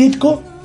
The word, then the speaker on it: Ditko: La vida y la obra de Steve Ditko. Lo dije bien. – Bien, no dijiste dicto. – Yo digo, de toda la vida digo dicto. Y me enteré que no era dicto hace pocos años.